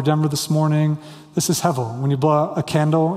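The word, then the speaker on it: hevel (הבל): That's a soft B in Hebrew. That's pronounced like a V, hevel, okay?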